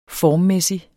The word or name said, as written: formmæssig